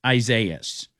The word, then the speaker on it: Isaias: It's Isaiah. It's like Isaiah, but it's two of them. So it's Isaias. I don't care what anybody else calls it. I don't care who calls it. It's Isaias.